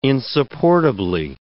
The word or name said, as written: insupportably